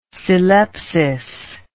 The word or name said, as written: syllepsis